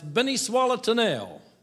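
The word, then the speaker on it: Beni swa Letènèl: Except in Haiti, it's pronounced "Beni Swalatanel." And I'd like y'all to join me with that. And if you don't know how to say that, just think, "Beni swallowed the nail." That's pretty close, okay?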